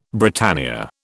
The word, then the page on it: Britannia: British English